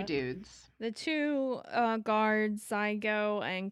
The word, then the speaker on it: Czigo: I only know that it's spelled C Z I G O, but I only know it's Zygo because I listened to the audiobook.